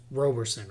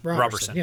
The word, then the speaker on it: Roberson: It's Roberson, right? Roberson.